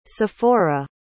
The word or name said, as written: Sephora